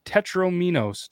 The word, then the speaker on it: Tetrominos: Or the Tetrominos. Did I say that right? Mm-hmm.